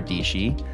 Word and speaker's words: Dietschy: Today, we have Sarah Dietschy, rhymes with peachy I mean, her tagline is proper, so that's fantastic.